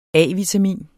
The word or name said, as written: A-vitamin